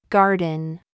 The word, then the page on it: gig: American English